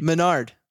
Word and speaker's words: Menard: What's plural for man? – Men. Menard. A-R-D. Menard.